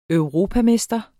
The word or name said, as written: europamester